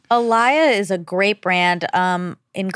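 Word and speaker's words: Alaïa: I don't even know how to say his last name. A-L-A-I-A. – Oh, Alaya. Yeah.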